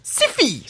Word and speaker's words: Sci-Fi: I found your podcast, and just as I aspired to give the show a look-see, I started seeing advertisements on Sci-Fi, pronounced Siffy.